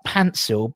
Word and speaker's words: Paintsil: And his name, because it was like Pansil, but he'd spell it Paintsil, and there was no one ever knew how to spell his name properly.